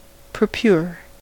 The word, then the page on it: purpure: American English